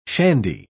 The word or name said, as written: shandy